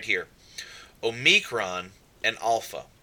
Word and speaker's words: alpha: So our pronunciation, alpha, ah, father, alpha, beta,